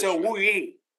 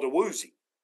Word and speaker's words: Awuzie: That's the pronunciation in Nigerian. In the United States, he's always been called a Woozy.